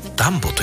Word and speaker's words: DUMBO: DUMBO と書いてダンボと読みます。